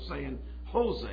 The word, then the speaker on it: San Jose: I said, well, we'd have you know out here, but don't call it San Jose. We call it San Jose. I said, the J is silent. Now it's like a H.